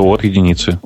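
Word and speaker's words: O(1): Как это произносится? O, O1 это по-русски, как говорят? – Так и говорится, от единицы.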